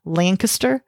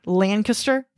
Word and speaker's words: Lancaster: They go to one of the males' houses in Lancaster, which through all of my research, local Lancaster people pronounce it Lancaster. – I've noticed the British way? – Lancaster. It's like real quick.